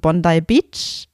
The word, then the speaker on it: Bondi Beach: Der wird geschrieben Bondi Beach, ausgesprochen Bondi Beach.